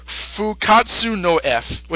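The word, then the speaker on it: Fukkatsu no F: And, like, you know, I think the original Japanese title is pronounced... I have it here. Fukatsu no F, which is the literal pronunciation of the title, and yeah, Resurrection F, why not?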